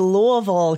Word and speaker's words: Louisville: Yes, my hometown is Louisville, Kentucky. You have to say it like you just took a giant bite of a caramel apple.